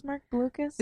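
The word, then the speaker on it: Marc Blucas: Oh, Mark Blucas is in it. – Who's Mark Blucas? – Is that not how you pronounce his name? – Oh, yep, it is. Okay. Mark Blucas. It is.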